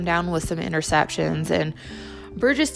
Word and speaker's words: Burgess: burgess I always get his last name wrong. I want to say Burgess, it's Burgess. but uh,